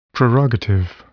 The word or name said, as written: prerogative